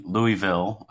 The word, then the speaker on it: Louisville: So I grew up in Louisville, Kentucky, Louisville, for others that don't like that pronunciation.